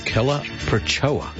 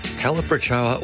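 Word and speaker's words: Calibrachoa: So Calaprochoa. – How do you spell it? – C-A-L-I. – Yeah. – B-R-A-C-H-O-A. Calaprochoa. – Calaprochoa. – Calaprochoa, or Cali for short.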